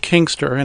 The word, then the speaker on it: Kinkstr: So your service called Kingster, and it's spelled KINKSTR. If anybody's looking for it, watch out for the spelling there.